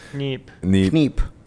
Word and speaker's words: Kniep: I said it was Kniep, and Landon's like, no, the K is silent. I was like, yeah, the K is probably silent. – My German isn't good, but I'm pretty sure in German the K's are never silent.